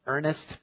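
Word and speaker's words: Eurnace: It's pronounced Ernest, but without the T. It's E-U-R-N-A-C-E, which I have never heard as a human name in all of my life.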